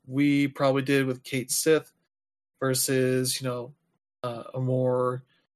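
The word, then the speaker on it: Cait Sith: In Final Fantasy VII Rebirth, Kate Sith is pronounced Kate Sith.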